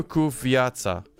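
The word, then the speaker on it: cu viața: Uh, ku uh, ku is hard is hard C. Okay, so it's Pario ku viața.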